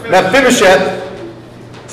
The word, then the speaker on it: Mephibosheth: Say, Mephibosheth, Mephibosheth yeah, I said it wrong. Mephibosheth, say, Mephibosheth was afraid, was afraid.